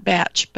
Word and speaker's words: Bach: It's spelled B-A-C-H, but Bach flower, yeah. – Right, but they pronounce it batch.